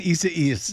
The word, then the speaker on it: Isaias: Hurricane, how do I say this, Desi Duane? Isaias. I see what you're doing. You're laughing at me. Hurricane Isaias. – There you go.